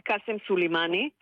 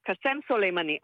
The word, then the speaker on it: קאסם סולימני: כמומחית אני מבטאת את השמות בצורה האותנטית, כקריינים, צריך לבטא בצורה הישראלית הכי קרובה, ולכן, לא להגיד קאסם סולימני, כי זה שם של ערבי, אלא קסם סולימני. אני אגיד קסם סולימני, ואתה יכול להגיד קסם סולימני.